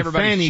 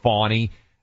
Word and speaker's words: Fani: Uh, and so I think it's kind of pronounced Fawny, uh, evidently, but that doesn't make sense phonetically at all.